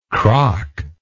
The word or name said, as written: croc